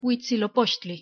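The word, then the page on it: huitzilopochtli: Nahuatl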